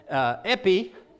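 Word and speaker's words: epi (ἐπί): And in Greek, on another place, it's pronounced "epi."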